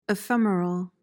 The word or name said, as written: ephemeral